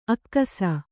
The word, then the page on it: ಅಕ್ಕಸ: akkasa